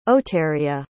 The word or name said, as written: oteria